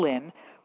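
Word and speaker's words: Lynne: Lynn when they put the announcement of my birth in the newspaper.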